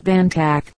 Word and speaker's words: Bontoc: The Bontoc Igorot have their center in the pueblo of Bontoc, pronounced Bantac, a Spanish corruption of the Igorot name Funtac, a common native word for mountain, the original name of the pueblo.